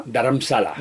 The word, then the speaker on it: Dharamsala: Y entonces se creó a ver si lo digo bien, Dharamsala se llama, Dharamsala. – Dharamsala, Dharamsala, que se convirtió en una especie de segundo Tíbet dentro de la India, ¿no? – Sí, gobierno indio, como somos de montaña, ¿no?